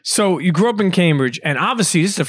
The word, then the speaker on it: Cambridge: Yeah, Cambridge.